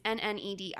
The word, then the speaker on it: Nnedi: It's Nnedi.